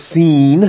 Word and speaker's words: Sin: But the Midbar scene, if you want to pronounce it scene, that might be closer to the Hebrew.